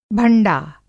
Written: भंडा

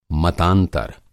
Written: मतांतर

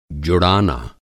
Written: जुड़ाना

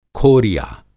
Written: खोरिया

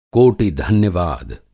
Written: कोटि-धन्यवाद

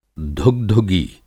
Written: धुगधुगी

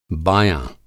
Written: बायाँ

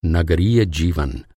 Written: नगरीय

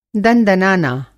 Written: दनदनाना

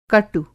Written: कटु

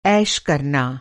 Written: ऐश

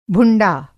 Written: भुंडा

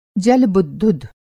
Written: जल-बुद्बुद

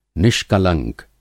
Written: निष्कलंक

